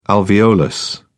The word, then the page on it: alveolus: British English